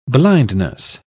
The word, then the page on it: blindness: British English